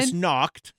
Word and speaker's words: Krampusnacht: I will say I've never been to a Krampus not celebration. – Krampusnacht. – All right, you said it correctly.